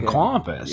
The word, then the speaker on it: Kwapis: It was actually written by Steve Carell and Ken Kwapis, I think is how you pronounce it. – Oh, Ken Kwapis.